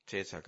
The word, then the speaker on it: Cäsar: Der Cäsar hat mal gesagt, der Julius, Cäsar gab es ja viele, der Julius, oder richtig ausgesprochen Cäsar, weil im Lateinischen gibt es das C ja gar nicht, das haben wir nur so gemacht.